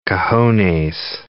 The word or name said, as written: cojones